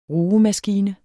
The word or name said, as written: RUGEMASKINE